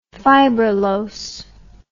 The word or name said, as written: fibrillose